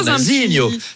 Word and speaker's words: Nazinho: C'est vrai que ça se prononce avec l'accent chantant. Nazinho.